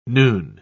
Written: noon

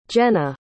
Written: jennah

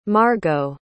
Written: margot

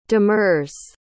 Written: demurs

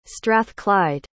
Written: strathclyde

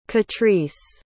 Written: catrice

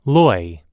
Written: loy

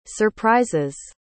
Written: surprise's

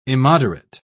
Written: immoderate

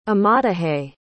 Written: amadahy

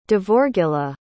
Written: devorgilla

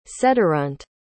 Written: sederunt

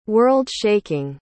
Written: world-shaking

